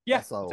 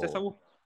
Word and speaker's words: Esaul: Esaul, yeah. – But you with a better emphasis on the S.